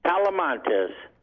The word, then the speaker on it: Talamantes: Talamantes. – Oh, you can spell that for me. – It's T-A-L-A. – Uh-huh. – M-A-N-T-E-S.